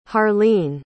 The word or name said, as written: harlene